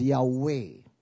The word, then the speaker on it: Yahweh: When you see the Lord as all caps, the original Hebrew for that would be YHWH, which many Bible scholars would pronounce as Yahweh.